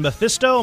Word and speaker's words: Mephisto: He has a match coming up on Sunday, August 6th, against current WWE champion Mephisto. Am I pronouncing that name right? – Yep, that's it.